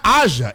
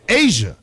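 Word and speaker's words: A'ja: You're saying it's not Aja, it's Asia.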